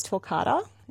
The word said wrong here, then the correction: torquata: Torquata. – The Torquata, is that how you say it? The coral gum? – Torquata.